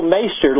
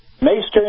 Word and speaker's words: Meister: It's Tool Meister, like on the Game of Thrones, Meister. – Oh, the Meister. Okay, I apologize. Okay. – It rhymes with taster. Meister and taster.